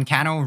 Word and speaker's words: Cannell: Sean Cannell rhymes with channel.